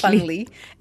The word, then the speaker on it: Fundly: And before that, also, there is a Fundly page. I hope I said that correctly. – Yes, Fundly. F-U-N-D-L-Y.